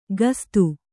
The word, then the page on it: ಗಸ್ತು: gastu